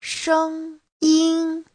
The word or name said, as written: sheng1yin1